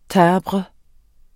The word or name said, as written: timbre